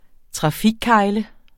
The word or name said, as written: trafikkegle